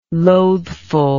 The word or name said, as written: loathful